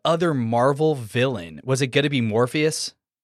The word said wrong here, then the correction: Morbius: You said the name wrong, but Morbius.